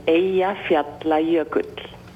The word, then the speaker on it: Eyjafjallajökull: The pronunciation is Eyjafjallajökull.